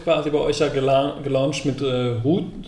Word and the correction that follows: Roots: Roots, mhm. so spricht man es richtig aus.